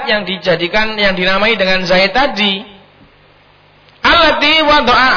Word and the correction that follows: wudi'a: allati wudi'a, bacanya wudi'a.